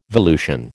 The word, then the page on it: volution: American English